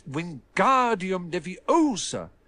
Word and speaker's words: Wingardium Leviosa: It's Wingardium Leviosa. Make the gar nice and long.